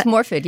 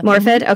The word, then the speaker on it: Morfydd: You see Maud, the main character, and you'll have to help me with the pronunciation of this brilliant actress's name, Morfydd Clark. – I think it's Morfydd, yeah. – Morfydd, okay.